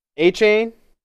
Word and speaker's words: Achane: Achane? Achane. That's how you pronounce his last name, I think.